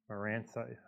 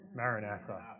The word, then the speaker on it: Maranatha: Maranatha, I can't even say it right. Maranatha. Oh, I appreciate your help.